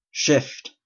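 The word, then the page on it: shift: Canadian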